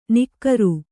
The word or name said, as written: ನಿಕ್ಕರು